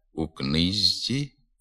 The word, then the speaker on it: у книжці: На письмі «зважся», «намажся», «у книжці», «у діжці», а вимовляти слід «звазся», «намазся», «у книзці», «у дізці».